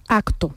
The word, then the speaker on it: acto: La palabra em, acto, ¿no? De hoy hay un acto en honor a, pues esa palabra acto hay muchos hablantes del español que la pronuncian acto, como si fuese una G.